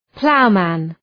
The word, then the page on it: ploughman: ‘plaʋmən